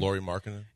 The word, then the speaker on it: Lauri Markkanen: Lauri Markkanen.